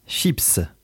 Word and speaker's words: chips: Chips, S se prononce, nom féminin pluriel.